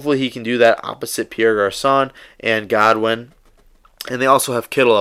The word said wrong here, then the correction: Goodwin: So, you know, that you compare those weapons, Godwin, Kittle, it's probably Goodwin, I'm pronouncing it Godwin.